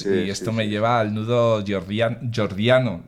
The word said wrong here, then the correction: Gordiano: ¿lo he dicho bien? Jordiano. – Gordiano. – Gordiano, sí, eso.